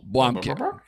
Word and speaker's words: Blomkamp: Neil Blomkamp.